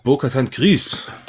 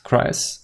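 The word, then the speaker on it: Bo-Katan Kryze: Bo-Katan, wie spricht man das aus? Kreis? – Bo-Katan Kreis. – Kreis. Okay.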